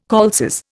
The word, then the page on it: calces: Indian